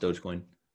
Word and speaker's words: Dogecoin: Yeah, no, it's pronounced Dogecoin.